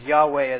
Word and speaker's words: Yahweh: You'll see, probably see "of the Lord" in capitals, but I'm going to pronounce that as Yahweh, as the original Hebrew is indicating there.